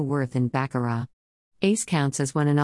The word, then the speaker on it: Baccarat: An enjoyable reality you require to find out about Baccarat besides that the T is silent, of course, it's obvious, Baccarat dash is likewise the truth that the word means absolutely no or nothing.